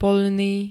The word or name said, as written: poľný